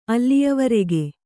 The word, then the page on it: ಅಲ್ಲಿಯವರೆಗೆ: Kannada